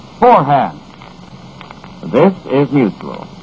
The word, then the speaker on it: Forhan's: Four-hand toothpaste is sold at all drug, department, and 10-cent stores. It's spelled F-O-R-H-A-N-S. It's pronounced four-hand.